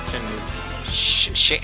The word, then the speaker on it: Shenmue: Shenmue.